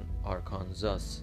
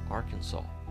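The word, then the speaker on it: Arkansas: آرکانزاس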